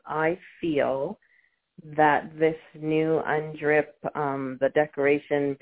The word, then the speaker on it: UNDRIP: It's UNDRIP in Vancouver.